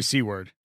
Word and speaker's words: Seaward: Larry Seaward. – Yes. – Yeah. – It looks like Seward to the rest of us, but he pronounces C-word. It Seaward.